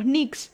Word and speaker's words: Knicks: Y San Antonio Spurs, el ex equipo de Manu Ginóbili, se enfrenta con los New York Knicks. Obviamente con la asistencia de la pronunciación, y vos me corregirás. – New York Knicks, sí. – New York Knicks.